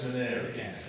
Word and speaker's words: Menary: I am Andrew Menary, pronounced Menary, as in canary or fairy or...